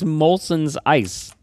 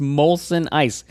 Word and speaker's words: Molson Ice: It's Molson ice. So not only do you know what it was, you weren't even saying it correctly.